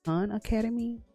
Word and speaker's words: Khan Academy: Now, additional sites, Cayenne Academy. I said like Cayenne Academy, but it's Khan Academy.